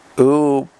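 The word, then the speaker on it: Umbanda: Mbanda, Portuguese pronunciation, Ubada, is a syncretic Afro-Brazilian religion that blends, or syncretic means it blends, African traditions with Roman Catholicism, spiritualism, and Indigenous American beliefs.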